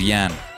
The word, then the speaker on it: Jan: You said Yaz. Yeah, it was Jan. Jan. It's spelled J-A-N. It's that Polish Jan.